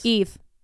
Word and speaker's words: Aoibh: A-O-I-B-H. How do you pronounce that? – A-O-I-B-H. A-O. What would A-O be? – Do you want to know how it's pronounced? – Please. – Eve. – No, it's not. – Yes, it's Eve.